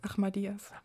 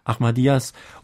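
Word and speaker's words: Ahmadiyya: Es gibt Ahmadijas, spricht man das so? – Ahmadiyas. – Ahmadiyas und so weiter.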